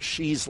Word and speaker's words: Hsu: So if you want to look that up on Amazon, Albert Shee's last name is spelled H-S-U.